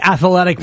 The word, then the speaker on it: athletic: And you got to say athletic with three syllables to be really...